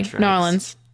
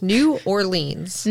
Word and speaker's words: New Orleans: Sorry, New Orleans. – No, I like pronouncing the whole two words. New Orleans.